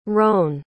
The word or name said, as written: rone